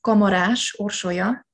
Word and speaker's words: Kamaras Orsolya: Well, it's a Hungarian name, so it's Kamaras Orsolya.